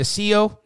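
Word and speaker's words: Cirelli: And that leaves Anthony Cio. Did I say that right? Happy to see you, double A. – Yes, you did.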